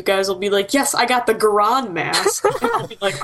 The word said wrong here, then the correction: Goron: Goron. Rhymes with moron.